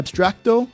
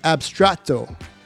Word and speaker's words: Abstrato: I think I might have said Abstracto, but it's Abstrato.